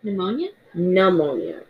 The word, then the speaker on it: pneumonia: Now, I need you to pronounce this one because I always get these two mixed up. – Pneumonia. – And the other one's ammonia. Okay, so pneumonia.